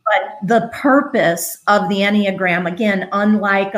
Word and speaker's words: Enneagram: And it was, there's a lot going on here how do you say the word again? Enneagram? – Called the Enneagram.